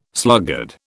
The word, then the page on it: sluggard: British English